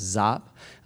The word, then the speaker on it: Giap: G-I-A-P, pronounced Zapp.